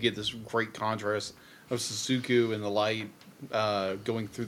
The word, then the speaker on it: Shizuku: Shizuku.